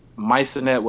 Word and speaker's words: Maisonette: but it's Maisonette with a hard T. It's not Maisonette.